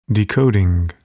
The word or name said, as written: decoding